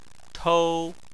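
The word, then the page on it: tou: tone 1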